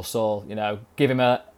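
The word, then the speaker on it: Saúl: Yeah, so wrote about Saul Niguez this week, although, sorry, I'm pronouncing it wrong, aren't I? – Saul, come on. – Saul.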